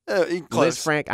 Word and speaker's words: Lisfranc: Am I saying that right? – Lisfranc. – I am so bad at medical terminology. – I think it's Lisfranc.